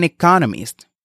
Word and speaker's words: economist: Hay cuatro sílabas en economista, con el acento en la segunda sílaba. Economist. Economist. Y también escuchamos la expresión he just finished writing.